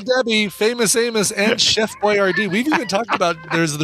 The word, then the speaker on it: Boyardee: Because we discovered his name is pronounced Chef Boyardee.